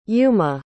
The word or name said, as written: yuma